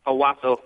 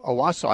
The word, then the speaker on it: Owosso: Let's talk to Owosso, Michigan. Did I pronounce that right? – Owosso. – Owosso, I'm sorry.